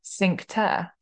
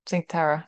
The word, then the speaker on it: Cinque Terre: We went to, I don't know how to pronounce it. I think it's Cinque. I think that's how Italians Yeah. – say it. Cinque Terre, yeah, Cinque Terre, Cinque Terre.